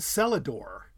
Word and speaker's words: cellar door: And they would not have pronounced the R. It would have been cellador, as in S. Just think of S-E-L-A-D-O-R, which sounds sort of pretty.